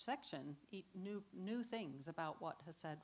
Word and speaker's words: chesed: It's also difficult to pronounce if you pronounce it properly because it has a little Hebrew thing at the beginning, you know, chesed, right?